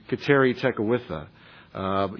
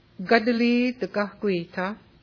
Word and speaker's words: Kateri Tekakwitha: Gadali Tekakwitha is the Mohawk pronunciation for Kateri Tekakwitha.